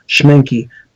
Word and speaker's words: Schmincke: Schmencke, it's a German company. It's spelled Schmincke. Schmencke.